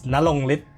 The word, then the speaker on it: Narongrit: Nalongrit Visit Kumpai. – Nalongrit. Oh, because it's spelled Narongrit, right? So yeah. I assume that it was supposed to be R.